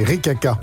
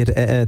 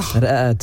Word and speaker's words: ra'at: Et est-ce que vous connaissez, alors c'est pas un mauvais jeu de mots de Mathieu Noël, les ricaca. – En fait, ça se prononce pas comme ça, en fait, en vrai. – Nouvel incident diplomatique. – C'est de l'arabe, ça se dit ra'at.